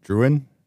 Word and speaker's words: Druyan: D-R-U-Y-A-N. Druyan? – Druyan. – Druyan, I think. – D-R-U-Y-A-N? – Yeah. – Druyan? And Druyan? – Sounds right. – Yeah. – Okay. So Carl Sagan and Ann Druyan